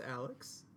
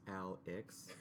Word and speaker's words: Alex: So my name's Alex, spelled A-L-E-X. Pronounced Al-X, like I-X if you grew up in the Midwest.